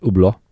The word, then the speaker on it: Hublot: E, w ogóle to jest marka zegarkowa, bo mówimy o marce Ublo, mhm. – pisany Hublot, a czyta się chyba właśnie Ublo,